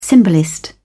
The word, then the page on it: symbolist: British English